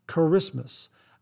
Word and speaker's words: Kiritimati: And here, I've learned a new word of a new destination out in the Pacific, Christmas Island, spelled K-I-R-I-T-I-M-A-T-I and pronounced Charismas.